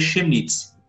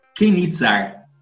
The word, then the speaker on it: Chemnitz: Eu iria ler e ia ficar muito próximo do francês, que eu ia ler Chemnitz, tá? mas não é Chemnitz, é Kenitzar.